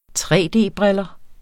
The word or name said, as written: tre-d-briller